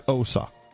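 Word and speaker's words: Xhosa: It's click OSA. – Okay. – OSA. – Oh, you have to click first? – You have to click.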